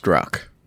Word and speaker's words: Strzok: It is pronounced Strzok.